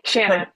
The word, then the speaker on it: Shanna: Shana, yeah.